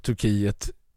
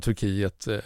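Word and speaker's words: Türkiye: Jag menar, exempelvis så har ju statsminister Magdalena Andersson sedan flera veckor tillbaka använt uttalet Turkie om Turkiet istället för Turkiet. Därför att, och när hon har pratat engelska så har hon inte sagt Turkey utan hon har sagt Turkie.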